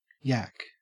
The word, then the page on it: yak: Australian English